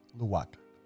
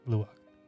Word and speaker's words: luwak: K-O-P-I. – Yeah. Kopi? – Luwak. – Luwak. – L-U-A-K. – Oh, luwak. Okay, I thought it was a G at the end. – They spell it L-U-W-A-K in America. – Yes. – Yeah. – W-A-K.